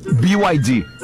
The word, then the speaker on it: BYD: BYD. – Que não pude deixar passar essa oportunidade. Faz questão de explicar que o correto é pronunciar... – BYD. – Falando o nome das letras em inglês.